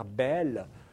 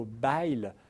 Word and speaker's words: Bayle: Bayle, philosophe français, et on prononce son nom maintenant de cette façon-là. Quand j'étais étudiant, j'entendais toujours parler de Pierre Bayle. On prononce plutôt Bayle, puisqu'il vient du Béarn, exactement comme François Bayrou.